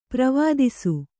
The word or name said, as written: ಪ್ರವಾದಿಸು